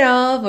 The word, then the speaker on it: of: Então, a log cabin made of earth and wood. O ed made não é pronunciado, o D virou rarara, of, of, sempre com som de ov.